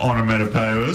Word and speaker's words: onomatopoeia: I do know what onomatopoeia is. – Oh, is that how you say it? – Yeah, that's right.